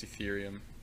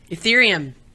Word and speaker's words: Ethereum: Yeah, I don't know a ton about crypto, but I think it's Ethereum. – Ethereum. – Yeah. – Ethereum, that's right.